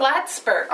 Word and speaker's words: Plattsburgh: And it's F-L-A-T? Flat? F-L-A-T Ford? Flat Ford? – Flat, no. – How do you spell it? – Plattsburgh. – Oh, Platt, okay. – Plattsburgh, P-L-A-T. – T-T-S-B-U-R-G, okay. – Yeah, Plattsburgh.